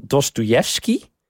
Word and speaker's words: Dostoevsky: dat jij bezig bent met Misdaad en Straf van Dostoevsky...